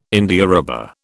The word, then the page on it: Indiarubber: British English